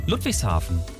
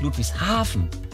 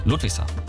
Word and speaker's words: Ludwigshafen: Oder Ludwigshafen, nicht Ludwigshafen. Nicht die Betonung auf Hafen, sondern auf Ludwig. Ludwigshafen.